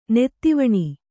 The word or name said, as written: ನಿಧುವನ